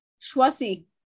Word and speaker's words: Choisy: Choisy became the de- director. huh? – it's Huh? Choisy, Choisy.